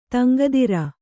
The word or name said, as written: ತಂಗದಿರ್